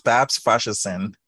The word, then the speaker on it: Babs Faseesin: No, I pronounced it wrong. Tell me, how do you pronounce your name again, Babs? – It's Babs Faseesin. Thank you.